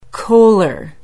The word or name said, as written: kohler